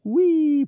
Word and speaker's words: Hwii: And I love how he even says it's pronounced Wee, but it's spelled H-W-I-I.